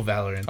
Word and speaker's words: Valorant: I didn't say it wrong, did I? – No. It seems very... I typed in how you said it, and it's exactly... – What, Overwatch meets fucking Counter-Strike? – No, Valorant. – Oh, exactly how I said it. – Yeah. Is how it's spelled.